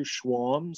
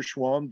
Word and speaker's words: Schwalm: If you just search in Facebook, Andrew Schwamm. So no, the last name's not easy to spell. – You got to spell that. – Yeah. S-C-H-W-A-L-M. And you'll see Andrew Schwamm, Desjardins Insurance.